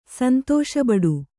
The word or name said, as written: ಸಂತಸಬಡು